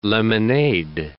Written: lemonade

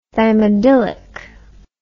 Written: thymidylic acid

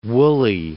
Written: Woolley

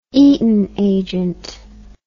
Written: Eaton agent

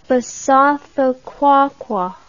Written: Basotho Qwaqwa

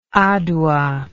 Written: aduwa

